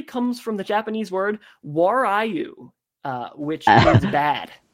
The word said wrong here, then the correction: warui: I can pronounce that as Warui. So yeah, it basically is, as you said, it's Japanese word for bad, Warui.